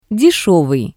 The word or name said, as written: Deshevii